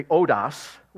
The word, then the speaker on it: hodos: Or sometimes the H you could actually not pronounce and just say odos.